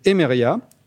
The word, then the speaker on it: Emeria: Alors, de, euh, de 2002 pardon, à 2008, vous devenez manager chez Emeria, comme ça qu'on le, dit.